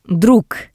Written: Drug

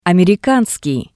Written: Amerikanskii

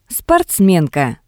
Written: sportsmenka

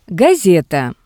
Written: Gaseta

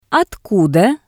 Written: otkuda

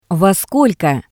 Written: Vo skolko

